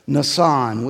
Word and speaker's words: Nisan: You see at the very top at 12 o'clock, that word, I'm going to pronounce it in, in Hebrew, it's pronounced a different way, but Nissan, we would even say Nissan, um, like the car.